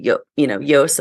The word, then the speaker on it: Joost: But Yoast and Marika of the SEO plugin Yoast, it's like that's how you pronounce his name in English. It's actually spelled J-O-O-S-T, but in the English language, we would say, Yoast, and so that's why they named the plugin mm-hmm.